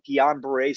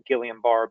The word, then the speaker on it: Guillain-Barré: And in 1991, I came down with an illness called Guillain-Barré syndrome. It was discovered by two French scientists. They sometimes call it Guillain-Barré, but it's pronounced Guillain-Barré.